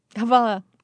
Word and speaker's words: hvala: Hvala.